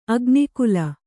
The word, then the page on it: ಅಗ್ನಿಕುಲ: agnikula